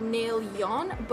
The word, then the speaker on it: Naelyan: It is spelled N-A-E-L-Y-A-N, although it's pronounced N-A-I-L-Y-O-N, so like Naelyon, but it's spelled like Yan, Naelyan, but you don't say it that way, you say it Naelyon.